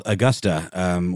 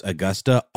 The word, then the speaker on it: Augusta: But it's funny, every time I've met people not from Georgia, they always pronounce Augusta, Augusta. And I've always been kind of blown away by that, because I'm like, have you not heard of Augustus? Like, it seems like a weird stretch to assume that you, and it's a hard aw sound.